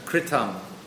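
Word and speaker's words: kṛtam: The next word, kritam, meaning was performed, you'll notice the dot, the diacritic under the R. That renders that sound into a r, like R-I. Right? So that word is pronounced kritam.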